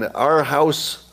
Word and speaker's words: Aarhus: There's a museum in Aarhus, that's how you pronounce it. It's in Denmark. Not our house, but our house.